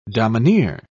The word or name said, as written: domineer